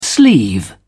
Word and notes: sleeve: British English

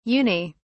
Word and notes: uni-: British English

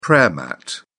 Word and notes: prayer mat: British English

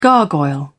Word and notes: gargoyle: British English

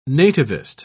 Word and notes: nativist: British English

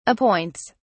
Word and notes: appoints: British English

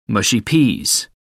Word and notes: mushy peas: American English

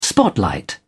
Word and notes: spotlight: British English

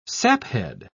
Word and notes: saphead: British English

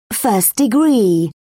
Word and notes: first degree: British English

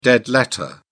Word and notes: dead letter: British English

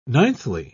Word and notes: ninthly: British English

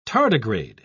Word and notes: tardigrade: British English

